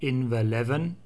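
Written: Inverleven

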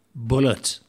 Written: Bulleit

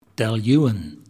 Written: Dailuaine

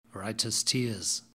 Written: writers tears

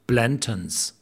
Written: Blanton's

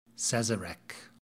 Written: sazerac

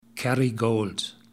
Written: Kerrygold

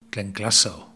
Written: Glenglassaugh

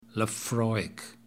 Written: Laphroaig

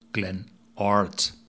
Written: Glen Ord

